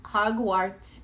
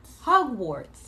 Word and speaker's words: Hogwarts: Hogwarts it is. I know I'm pronouncing it wrong, but... – Hogwarts. – Yeah, there you go.